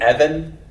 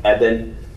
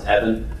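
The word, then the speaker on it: Evan (אבן): It's really Evan. It's the B. I know it's, you think that's supposed to be ba, like Eben, but it's pronounced Evan.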